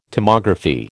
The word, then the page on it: Tomography: American English